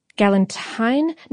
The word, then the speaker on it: galantine: Galantine? No, it's spelled like Galentine's Day, but I think it's pronounced galantine. And